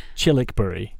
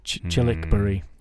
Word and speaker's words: Childwickbury: So it's pronounced Chilwickbury, mm-hmm. but it's spelt Childwickbury, so Childwickbury, but it's Chilwickbury. Mm.